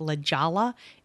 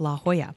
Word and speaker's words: La Jolla: And I know how to pronounce that, even though it looks like La Jolla, it's La Jolla.